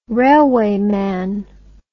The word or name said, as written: railwayman